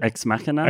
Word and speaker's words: Ex Machina: Ex Machina.